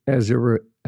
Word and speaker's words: Aziraphale: It's Aziraphale. – Azrael. – It's funny because in season two, there's a demon trying to go after him, and he's trying to pronounce his name just like I did.